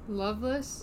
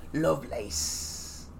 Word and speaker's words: Lovelace: Loveless? – Mm-hmm. No, he pronounces it Lovelace.